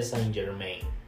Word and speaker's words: Saint Germain: Así, Saint Germain.